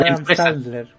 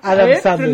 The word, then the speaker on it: Adam Sandler: Y en ese momento tú tenés que aprovechar así como para venderle la eso lo aprendí con una película de Adam Sandler. – ¿De Adam quién? Adam Sandler. Pronuncia bien la weá.